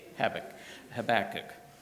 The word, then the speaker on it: Habakkuk: you pronounce it like tobacco with a K, Habakkuk," and that helped me remember that.